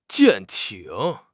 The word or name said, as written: 舰艇